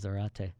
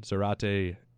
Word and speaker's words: Zárate: When really good pronunciation there, too, is Zárate. – Listen, Zárate rhymes with karate.